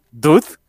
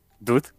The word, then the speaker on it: Dewd: Ella lo que quiere es explorar el espacio se va con uno de sus cuates que se llama, o sea, no se escribe así, pero se pronuncia así, Dud, D-E-W-D, Dud.